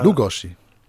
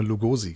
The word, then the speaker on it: Lugosi: Die erste Silbe wird betont. Lugoshi. Lugoshi. Lugoshi. Ja. Es klingt sehr merkwürdig, vor allem ist es sehr ungewohnt, wenn man die ganze Zeit immer, ich habe immer Lugosi gesagt.